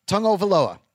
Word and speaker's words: Tagovailoa: Let's move on to Tua Tagovailoa. And I still have no idea how you get that sound tongue from T-A-G-O. I have no idea, but that's how you pronounce it.